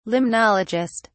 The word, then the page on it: limnologist: British English